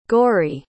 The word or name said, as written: gorrie